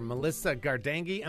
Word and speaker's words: Melissa Gardenghi: Great to be back with you, Theology Thursday, with our friends at Bob Jones University today, talking to Dr. Melissa Gardenghi.